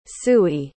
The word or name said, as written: suey